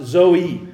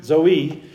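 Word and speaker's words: Zoe (ζωή): The Greek word for life here in verse number 4 is, it's spelled funny, but it's basically pronounced Zoe. Zoe.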